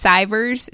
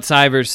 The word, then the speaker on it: Sivers: We have a great story from the book Hell Yeah or No by Derek, I think it's Sivers is how you pronounce his last name, S-I-V-E-R-S.